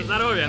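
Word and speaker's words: Nostrovia (на здоровье): Nostrova, right? – Nostrovia. – Nostrovia, that's how you say it.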